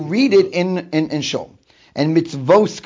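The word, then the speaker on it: mitzvos (מצות): Now, I pronounced it in mitzvos because that's how it's punctuated. And when the Baal Kori reads it, he's going to read it with the word by pronouncing it as mitzvos. Nevertheless, it's not written mem tzadi vav vav tuf. It's written with only one vav. It could be pronounced mitzvas.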